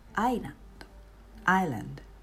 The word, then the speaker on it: island: まずはアイランド、島のことですね。アイランド、 ISLAND と書いてアイランド、 S が入りますが、発音はしません。